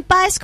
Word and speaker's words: Páez: O si no, agrégueme a Instagram como Karen Páez, guión bajo. Creo que tiene guión bajo Karen Páez. Karen Páez, así nomás. Karen Páez como Fito Páez, así se escribe mi apellido.